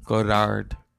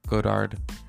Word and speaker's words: Godard: The desire, the love of film, the love of French filmmakers like Jean, I almost said his name wrong. Godard. Godard. Yeah.